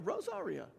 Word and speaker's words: Rosaria: And particularly in our culture, when we're talking about being good neighbors and reaching our neighbors, Rosaria, I think that's how you say her name, Butterfield, somebody help me. Yeah, I think that's right. I wrote this book. Is that right? Yeah, Rosaria. That's probably much better enunciation.